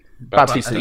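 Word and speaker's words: Bautista: And I think Batista is actually like, is it Batista? Is it? Batista. Yeah. Sorry, I'm butchering it.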